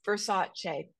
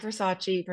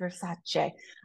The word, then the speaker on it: Versace: Versace, not Versace, Versace.